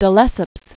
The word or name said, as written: de Lesseps